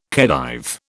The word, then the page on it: Khedive: British English